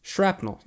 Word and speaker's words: shrapnel: Shrapnel. Yeah, no T. – So it is pronounced with a P.